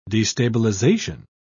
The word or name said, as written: destabilization